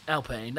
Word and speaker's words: Alpine: Is it Alpine or Alpine? – Alpine. That's how they tell you to say it.